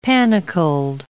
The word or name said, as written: panicled